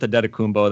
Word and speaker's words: Antetokounmpo: I go with Adetokounmpo. That's what it sounds like how he pronounces it.